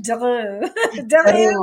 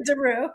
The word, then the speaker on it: DeRieux: DeRieux or DeRieux, however you want.